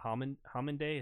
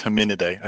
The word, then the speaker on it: Hominidae: They if you follow them back, and you have to go back far enough, I think it's a hominidae. Does that sound like a proper pronunciation? – Yeah, I think it's hominidae. I think there's an extra syllable in there.